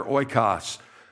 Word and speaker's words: oikos: and the word is oikos. Everybody say that word together, oikos. Basically, the way you spell it, it's a Greek word.